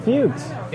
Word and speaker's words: Fuchs: F-U-C-K-S. No, F-U-C-H-S. – Fuchs. – Yes. Dr. Fuchs. F-U-C-K-S. Not that. – No. – F-U-C-H-S.